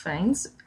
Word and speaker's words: Fiennes: And the film did win Best Sequel, and Ralph Fiennes, I can never say his name.